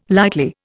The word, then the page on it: lightly: Indian